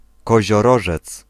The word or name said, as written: Capricornus